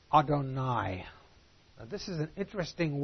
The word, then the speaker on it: Adonai: If you're reading in Hebrew and you see the word Jehovah, you never pronounce that. Orthodox Jew will never pronounce it. When you're reading the Hebrew text, when you see the word Jehovah, you say Adonai, my Lord's plural.